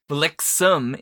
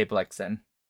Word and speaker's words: Blitzen: Blixum in the original, but we say Blixen.